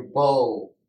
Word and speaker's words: beau: like your bow, which we would, in English, we would say B-O, but they spell a bow like a paramour, like a boyfriend. – Yeah. – They spell it B-E-A-U, right?